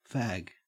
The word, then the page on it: fag: Australian English